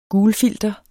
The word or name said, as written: gulfilter